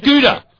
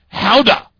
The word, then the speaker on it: Gouda: I have found out that the pronunciation of Gouda is not Gouda. It's Gouda.